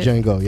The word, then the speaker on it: Django: Django.